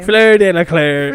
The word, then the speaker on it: Delacour: I don't think that's how you say it. – It's not. It's Fleur Delacour. – No, Fleur Delacour. You're putting like an extra L in that name. – Fleur Delacour.